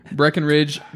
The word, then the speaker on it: Breckenridge: This is, again, this is a Breckridge. – Breckridge. Maybe we'll dip in the other stash. – You just take out those middle syllables and you're fine. Breckenridge Brewery.